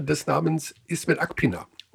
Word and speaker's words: Ismet: Das, das I, das große I mit Punkt drauf, also bei Ismet, mhm. wird ausgesprochen wie das deutsche I in Miete. Miete. – Also lang I. – Wie langes I. Langes I, Ismet.